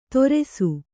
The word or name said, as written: ತೊರೆಸು